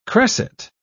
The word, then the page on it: cresset: British English